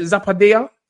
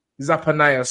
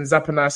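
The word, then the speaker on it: Zephaniah: I think, how you say it? Zappadia? – Zappanaya, sorry. Rest in paradise, Mr. Benjamin Zappanaya.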